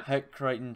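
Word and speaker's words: Hec Crighton: And even just, so now at the end of your career at Western, you earned the I don't know if I'm pronouncing this right, but the Heck Carrington Trophy. – Heck Crichton, yeah. – Heck Crichton Trophy.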